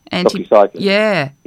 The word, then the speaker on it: doxycycline: and doxycycline. Is that how you say it? From the doxycycline. – Yeah.